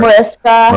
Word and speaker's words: Mariska: love affair with SVU with Marisha. How do you say her last name? Har- Mariska.